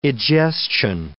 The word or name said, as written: egestion